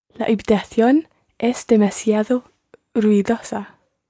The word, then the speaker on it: ruidosa: And the word for noisy in Spanish is ruidosa. – Ruidosa. – Ruidosa. – Ruidosa. – Ruidosa. – Ruidosa. – Sí. There are four syllables in that word. Ru, I, do, sa.